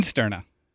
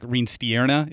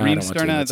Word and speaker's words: Reensterna: Just like it's spelled. – Yeah, yeah. You can put a little accent on the I and say like Reensterna if you want. But Reensterna, okay. – Yep. No, Reensterna, that is typically how I just tell people because it's, it is kind of phonetic.